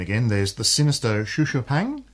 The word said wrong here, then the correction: Xu Xu Pang: It's just Chu Chu Pang, actually.